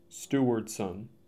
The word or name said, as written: Stewardson